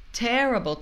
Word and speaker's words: terrible: Terrible, terrível. A primeira sílaba é a sílaba tônica. Terrible. Então, depois, a gente vai falar a. Terrible. Terrible. Ok? E o vogal O, A-E-I-O, também isso acontece.